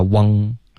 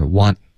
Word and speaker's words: want: OK want 的发音不能发 wang OK wang 是 want OK, 要动舌尖。